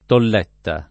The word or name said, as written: tolletta